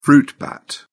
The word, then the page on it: fruit bat: British English